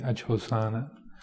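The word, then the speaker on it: ajjhosāna: Ajhosana, A-J-J-H-O-S, long A-N-A, ajhosana.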